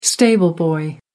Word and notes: stable boy: American English

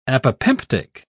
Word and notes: apopemptic: British English

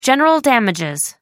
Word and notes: general damages: American English

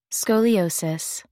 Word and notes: scoliosis: American English

